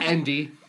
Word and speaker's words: Ende: I have Ende. – Ende? – Ende. – E-N-D-E. You tell us how to pronounce that, dear listener. – Yeah. Yeah. Endy.